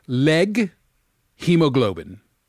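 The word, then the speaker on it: leghemoglobin: a leg, let me, I'm trying to pronounce legumoglobin? – This again. Legumoglobin? Leg hemoglobin. Okay. It's like a leg, like a human leg.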